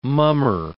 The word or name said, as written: mummer